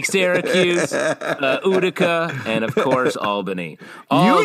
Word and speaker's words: Albany: Pronounced Albany in this show, which I think us upstate boys know. It's Albany.